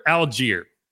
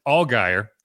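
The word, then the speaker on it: Allgeier: All season, I even had him on my fantasy roster at one point this season, but in my head, I pronounced it Allgaier. And then I watched a Falcons game and they said Algier. And I was like, wait, that's how you say it? And the reason that's funny to me is because there is a place here called Algiers. And I still was saying it the wrong way.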